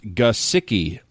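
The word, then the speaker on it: Gesicki: This comes from Thomas Roberts, and Thomas Roberts, actually, the heading on this says Mike Gasicki, and it says, "Come on, guys, it's pronounced Gasicki, like guh, kind of like the word gun, then the word sick, emphasized and followed by the word key. You know all three parts. Let's get it started right."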